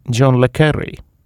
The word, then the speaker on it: John le Carré: Näiteks sellistes, milliseid on kirja pannud meie selle nädala raamatu autor ja üksiti ka peategelane John le Carré. Jah, nii seda pseudonüümi maailmakuulsel kirjanikul, kodaniku nimega David John Moore Cornwell, hääldatakse. Ehkki kirjapilt viitab prantsuse pärasusele.